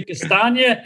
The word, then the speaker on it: Castagna: I'm going to say Castagna and Afrezza. I need that. – I always pronounce it wrong. – Castagna, lasagna. Yeah, I'll get it right.